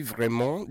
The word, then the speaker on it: vraiment: When you want to say, I'm really sorry in French, yeah, you say, je suis vraiment désolé.